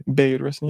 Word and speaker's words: Bayard Rustin: Bayard Rustin. Am I saying that right? – Bayard Rustin.